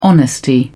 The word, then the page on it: honesty: British English